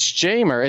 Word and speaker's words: Jaimer: It's Jamer. It's J, like at the hard enunciation on the J. It's J-A-Y. Jamer. – We've been saying Jaimer Candelario since... – Years. – Yeah. – We've been saying it wrong. – Since he was 12. – Years. – Since he used to call in. He said this is Jaimer from Detroit. – It is Jamer.